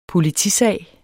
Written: POLITISAG

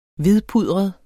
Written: hvidpudret

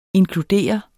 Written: inkludere

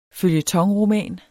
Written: føljetonroman

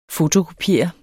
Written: fotokopiere

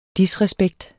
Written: disrespekt

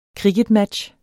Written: kricketmatch

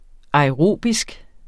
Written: aerobisk

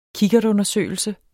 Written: kikkertundersøgelse

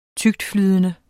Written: tyktflydende

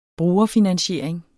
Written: brugerfinansiering

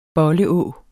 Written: bolle-å

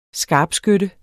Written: SKARPSKYTTE

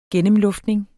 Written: gennemluftning

